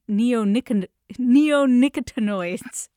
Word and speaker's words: neonicotinoids: And I'm thinking particularly of neonicotinoids.